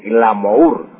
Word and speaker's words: glamour: En francés se escribe, como usted dice, glamour, glamour. Pero se pronuncia glamour.